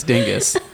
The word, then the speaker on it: Dingess: Stop trying to be fucking fancy. Say it like you're white. It's Dingess.